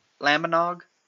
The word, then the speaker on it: Lambanog: But so what they do is they get the Lambanong, however it's pronounced. – Lambanog? I think it's Nog, like eggnog? Lambanog? – Yeah.